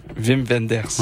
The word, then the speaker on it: Wim Wenders: Puis moi, je te pensais que c'était Wim Wenders. On pourrait leur demander comment prononcer. En fait, en fait, c'est toi qui as raison. – Parce que W en allemand, c'est V. Wim Wenders.